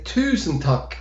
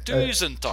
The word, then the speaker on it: tusen takk: Tuck, or you can say tusen tack, which is mille grazie. – Tusen takk.